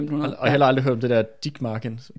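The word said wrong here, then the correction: Ditmarsken: Dittmarsken. Dit, Dittmarsken. – Ja, mener det udtales, ikke?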